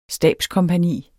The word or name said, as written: stabskompagni